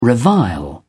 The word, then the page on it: revile: British English